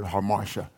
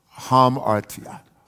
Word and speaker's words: hamartia: Sin in the Greek is, we've often in America called it harmatia. But I think the Greek pronunciation would be like hamartia.